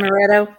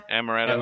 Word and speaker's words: Amaretto: So yeah, But yeah, I ordered Amaretta, or how do you say that? Isn't it a liquor? – Yeah. Amaretto. – Amaretto.